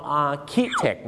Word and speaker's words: architect: องค์ chaotic นะครับเขาไปเปลี่ยนการสะกดจาก chaotic เป็นตัว k นั่นเองครับ chaotic ก็คือ adjective ของคำว่า chaos ที่แปลว่าความยุ่งเหยิงวุ่นวายนั่นเองครับและอีกหนึ่งคำนะฮะที่เราเจอกันบ่อยๆที่บ้านเรานั้นมักจะใช้กันผิดนะครับคือคำว่าสถาปนิกครับออกเสียงว่า architect นะครับ